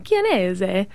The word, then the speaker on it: Kanese: It's the phonetic pronunciation of my Italian last name, Kanese, but the Americanized version, as well as it sort of sounds like, if you think about it, the words can I see,